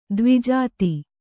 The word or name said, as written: ದ್ವಿಜಾತ